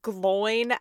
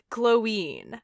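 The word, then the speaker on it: Glóin: it's not pronounced Gloin as in groin. Are you kidding me? No, it's Gloin because there's an accent over the I.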